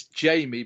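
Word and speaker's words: Jammie: So it's Jamie, but it's spelled Jammy. Like Jammy.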